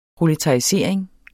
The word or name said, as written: proletarisering